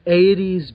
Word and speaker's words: Aedes: Aedes being the genus of mosquito that we're targeting. AED. – Right, the Aedes aegypti, but it's pronounced like... You know, we love the 80s.